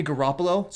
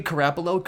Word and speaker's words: Garoppolo: Of course, I don't know why Jimmy Garoppolo... Sorry, I mispronounced his name. I don't know why Jimmy Garoppolo couldn't do the same kind of things he was doing in Kyle Shanahan's offense.